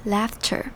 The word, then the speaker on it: laughter: 首先我们来看这句话的第二个字 laughter。 laughter 要注意的地方是它的重音在第一个音节，也就是 L-A-U-G-H 这边 laughter，而 A-U 是念成 A 的声音 laughter laughter。 Their laughter spread，